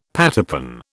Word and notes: pattypan: British English

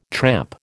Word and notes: tramp: American English

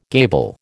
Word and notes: gable: American English

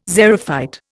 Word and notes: xerophyte: Indian

